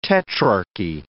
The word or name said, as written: tetrarchy